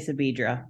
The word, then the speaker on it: Sabedra: It looks like Sabedra, but I say Sabedra.